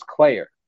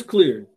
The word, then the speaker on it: Clear: I always thought it was Claire, but it's Clear. – But it's supposed to be like Claire. – So do they pronounce it Claire and just spell it Clear? – No, it is Clear.